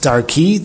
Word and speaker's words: Darki: It's pronounced Darki. The Kri is Darki, but the Kasiv is Darko with a Vav.